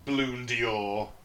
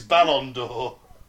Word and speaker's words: Ballon d'Or: You mean it's not Bloon Dior? It's Ballon d'Or. I've been saying it wrong all these years.